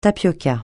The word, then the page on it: tapioca: francais